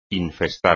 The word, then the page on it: infestar: espagnol